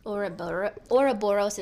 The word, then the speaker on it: Ouroboros: You think I'm saying that right? – Ouroboros is how they say it.